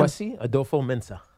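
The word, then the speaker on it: Kwesi Adofo-Mensah: Kwesi Adolfo Mensah.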